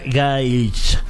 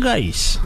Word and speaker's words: Gaich: Pueden decir Gaich. Okay. O Fer Gay, o Fer Gay, como ustedes. La verdad es que como ustedes quieran y prefieren, como se sientan más cómodos.